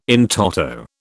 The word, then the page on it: in toto: British English